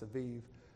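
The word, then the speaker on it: Abib: or the old name would be Aviv, A-B-I-B, I think, pronounced Aviv, is going to be the first month of their new calendar year.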